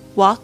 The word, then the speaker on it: walk: Okay, don't be scared to walk alone. Walk 啊，记得它的发音，其实那 al 的声音呢是发啊这个声音。